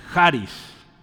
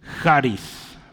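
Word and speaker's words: Haris (χάρις): Esa es la palabra griega para gracia, es Haris, ¿sí? con acento en la, el acento acá está, Haris, Ese es gracia, ¿sí?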